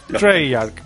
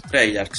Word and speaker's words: Treyarch: Treyarch, ¿no es? – Treyarch, sí.